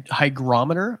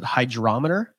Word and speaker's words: hygrometer: Hygrometer. I always pronounce it hydrometer because I thought that's like when you read it, a G can make a J sound like hydrometer, right?